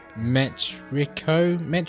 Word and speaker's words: Metrico: And the last game that I kind of played a bit of was, I don't know how to say this, Metrico? – I think that's right, yeah.